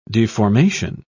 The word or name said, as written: deformation